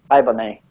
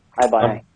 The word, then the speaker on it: Haibane: So I liked the, the Haibane, was it Haibane? Is that how you say it? – Haibane. – Haibane. – Haibane, yeah.